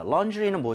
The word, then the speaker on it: laundry: 이것을 바로 laundry라고 하는데 A-U-A-W는 발음할 때 O예요? 어예요? – 어. – 어에 더 가까워요. 완전 어는 아니지만 입모양을 O보다는 벌려야 됩니다.